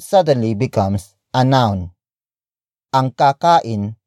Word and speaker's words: kakain: Kakain. Kakain. Kakain is spelled as K-A-K-A-I-N.